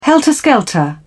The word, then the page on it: helter skelter: British English